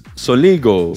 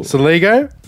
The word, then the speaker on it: Soligo: Jay, how do you spell it? – Jake. J-A-K-E. – Yeah, okay. – S-O-L-I-G-O. – Saligo. – Saligo? Awesome.